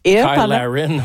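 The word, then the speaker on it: Cyle Larrain: Il y a un des joueurs de l'équipe canadienne qui s'appelle, ça s'écrit Cyl, C-Y-L-E, et Larrain. Mais bon, vous le prononcez à l'anglaise. – Kyle Larrain, oui, c'est ça.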